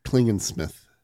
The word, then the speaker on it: Klingensmith: So the researcher Beth Klingensmith has suggested You just said a better name wrong, and then say him again. – Klingensmith. – You said Klingenman, and that's better than yeah. Klingensmith.